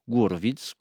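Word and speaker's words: Horowitz: Eh bien cette semaine, le musicien et pianiste à l'honneur n'est autre que Vladimir Gourovits, comme le prononceraient sans doute les Russes, souvent considéré évidemment comme l'un des plus grands pianistes de l'histoire.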